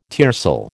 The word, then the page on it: tiercel: American English